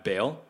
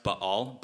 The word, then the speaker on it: Baal: Now, a little bit of background on the god Baal. The word Baal, I mean, technically it's pronounced Baal, but don't worry, it's too hard to say over and over again, so just say Baal.